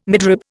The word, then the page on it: midrib: Indian